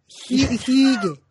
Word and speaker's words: hygge: the Danish art of hygge. Hygge. Hygge. She... – That's nice pronunciation, sister.